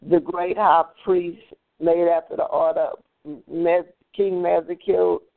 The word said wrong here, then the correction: Melchizedek: um, King Mezek, uh, I can't even pronounce it, Melchizedek.